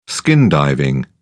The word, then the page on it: skin diving: British English